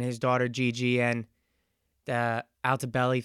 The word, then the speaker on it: Altobelli: I'm just going to look it up real quick because, yeah, Altobelli. Okay, so I got it right. Just wanted to make sure I was pronouncing it right. The Altobelli family, along with several others.